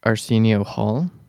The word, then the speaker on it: Arsenio Hall: is Arsenio Hall. – I think mm-hmm. I'm saying that correctly. That's good.